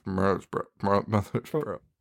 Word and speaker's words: Mothersbaugh: Mark Mothersbaugh.